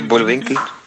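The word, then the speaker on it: Bullwinkle: Как ты не говорил, до сих пор не смотрел приключения Рокки и Бульвинкель. Как это читается? – Рокки Бульвинкль. – Бульвинкль. – Кль, да.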